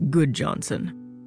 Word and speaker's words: Gudjonsen: My name is Eric Gudjonsen, spelled G U D J O N S E N, but pronounced Goodjohnson.